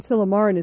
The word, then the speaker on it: Silymarin: C-H-I-Z-A-N-D-R-A. – Right. Yeah, that's how I saw it in the literature. And then Silymarin is spelled like Silly Marin with one Y. – Right. – I apologize to everyone in Marin. S-I-L-Y-M-A-R-I-N.